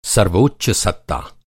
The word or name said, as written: सत्ता